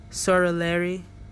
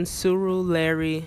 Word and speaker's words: Surulere: If you're in Nigeria, you're within the Lagos area, Sorileri.